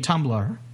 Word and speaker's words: Tumblr: You mean Tumblr, right? – Tumblr. – Yeah, okay. – Yeah, but it's Tumblr is spelled T-U-M-B-L-R. – Yeah, that's the Tumblr without an E at the end.